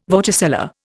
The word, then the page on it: vorticella: Indian